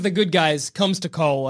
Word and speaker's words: Cole: Cole Young, and he'll be like, I gotta protect you. – Yeah, this will help you. It rhymes with Paul.